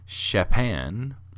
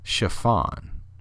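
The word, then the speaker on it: Xaphan: So that's the Secret Chiefs, are off of their volume from the Masada Songbook, which is Chopin, which is actually spelled with an X. It's X-A-P-H-A-N. Maybe it's Chiffon.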